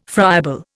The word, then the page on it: friable: Indian